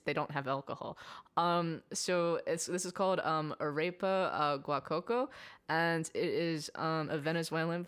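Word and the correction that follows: Arepera Guacuco: I think it's wait, is this a typo or is this how it's spelled? – Yeah, no, that's how it's spelled. – Oh, so it's Arepera Guacoco. Guacuco. Yeah. – Yes.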